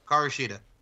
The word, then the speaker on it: Hikaru Shida: Oh, Hikaru Shida. – Hikaru Shida.